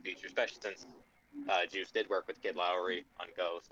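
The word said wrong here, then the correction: Kid Laroi: So I think I'm saying that guy's name wrong. – Leroy. – Yes, I'm sorry. I know. I literally looked at his Twitter, and he says how to pronounce it on his Twitter. I just forgot, and I've just heard Lowry, so that's what I've been saying.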